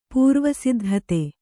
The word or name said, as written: ಪೂರ್ವಸಿದ್ಧತೆ